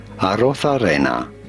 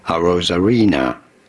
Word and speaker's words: Arozarena: In Spanish, his name would be said as Arozarena. But in English, or in America, it is generally said as Randy Arozarena. – Ah. – Randy Arozarena. – Randy Arozarena.